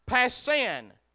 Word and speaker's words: pasin: The word all comes from a Greek word that is spelled P-A-S-I-N, passin.